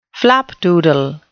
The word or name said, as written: flapdoodle